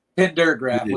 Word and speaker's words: Pendergraf: Pendergraf with a D.